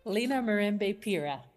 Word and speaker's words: Lena Mirembe Pira: Lena Mirembe Pira.